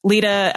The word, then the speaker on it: Leda: But Lita, L-E-D-A, the Greek way.